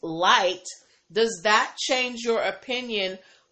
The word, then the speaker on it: LYT: I pronounced it Lit, but it's actually pronounced Light. Okay, thank you, TS. So you remember this? You did remind me. I'm bringing it back up today again because I was pronouncing the name of the service wrong. I was just pronouncing it Lit like Lyft, but it was pronounced Light, as in, you know, a light.